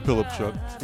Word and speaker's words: Polipchuk: Next week will be Ivan Polipchuk. I'm hoping I'm pronouncing that correctly. Probably not. But sounds like Polipchuk.